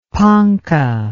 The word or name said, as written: ponca